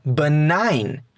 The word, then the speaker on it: benign: Por ejemplo, con la palabra sign, que se escribe S-I-G-N, pero se pronuncia sign, señal, sign. o con la palabra benign, benigno, benign.